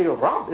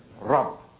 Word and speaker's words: Rabb: Actually, Rabb is really one syllable, isn't it? Rabb. When you say the Rabbun is two syllables, when you pronounce the sign at the end of the letter, but it's actually Rabb, one syllable letter, but so rich in meaning.